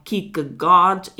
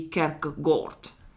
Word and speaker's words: Kierkegaard: But the existentialist will usually taken to be the first existentialist is Søren Kierkegaard. That is the correct pronunciation of the Danish. While it looks like Kierkegaard, it's actually Kierkegaard.